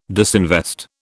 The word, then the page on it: disinvest: British English